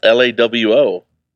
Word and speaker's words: LAWO: It's spelt LAWO. – I know, but it's German and they say their W's like V's.